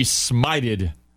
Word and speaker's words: smite: You don't want to be smited. – Oh yeah, no. – See that? – Totally different. – Totally. – Yeah. Remember, smite rhymes with bite.